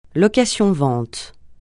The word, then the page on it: location-vente: francais